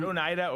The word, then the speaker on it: Oneida: By that logic, every fat person should sue Onietta Cutlery for making large spoons. Like, or is it Oneida? Oneida. Yeah. I forget how you say it.